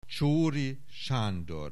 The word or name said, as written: Csoori Sandor